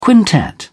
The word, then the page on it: quintet: British English